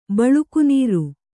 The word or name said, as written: ಬಳುಕುನೀರು